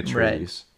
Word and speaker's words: Atreides: I want to say Atreides.